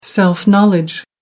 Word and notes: self knowledge: American English

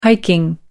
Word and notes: hiking: American English

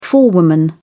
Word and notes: forewoman: British English

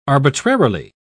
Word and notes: arbitrarily: British English

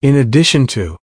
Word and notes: in addition to: American English

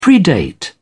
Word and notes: predate: British English

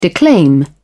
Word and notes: declaim: British English

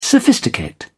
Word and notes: sophisticate: British English

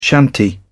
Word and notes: shanty: British English

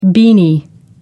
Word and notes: beanie: British English

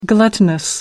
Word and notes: gluttonous: American English

